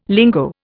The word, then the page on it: lingo: Indian